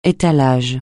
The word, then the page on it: étalage: français